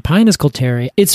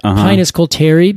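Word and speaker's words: Pinus coulteri: Colter pine, Pinus colteri. It's funny when you said that, Pinus colteri, because I always pronounced it Pinus colterii, and that's not it. There's only one eye there.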